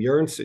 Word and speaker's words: Joens: And then my last name, Jerns. It's spelled J-O-E-N-S. My first name is My first name is S-T-E-F-A-N. Last name is J-O-E-N-S. Many people would think that's Steve Jones.